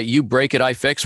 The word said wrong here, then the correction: UBREAKIFIX: We'll we'll go in, uh, and I keep saying it wrong. You break, I fix. I put in the word it there.